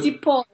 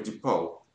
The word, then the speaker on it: DePaul: Дипол. – Дипол. – Да. Дипол. – Дипол. Как пишется? Ди? – DEPAUL. – А, такой дипал.